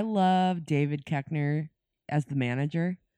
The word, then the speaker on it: Koechner: Koechner.